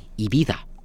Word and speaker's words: Ibiza: And so I looked it up using my ChatGPT, the co-pilot in there, and it's Ibiza. – Okay, so I was right. I wasn't being pretentious. – No, I was doing a more Canadian-Americanized version, so sloppy me, so Ibiza.